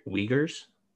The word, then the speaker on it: Uyghur: On that article I posted on our website, vertical relationships, I kind of link to some articles on those that you can, I think they're the Uyghurs. I think that's what they're called. It's not spelled Uyghur, but that's how you pronounce it, I think.